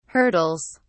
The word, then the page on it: hurdles: British English